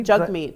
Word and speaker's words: Jagmeet: I found out that it's Jagmeet Singh, but I was saying Jagmeet Singh because that's how it phonetically looks. But if you go onto his Twitter account, it says Jagmeet like hug meat.